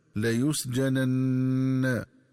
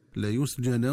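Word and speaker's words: ليسجنن: ينبغي التنبه للنطق الصحيح للفظ لا يسجنا، فيقرأ بنونين، النون الأولى مفتوحة مخففة والنون الثانية مفتوحة مشددة هكذا لا يسجنا. ومن الخطأ قراءته بتسكين النون الثانية هكذا لا يسجنا ولا يكون من الصاغرين.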